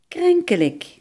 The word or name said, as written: ziekelijk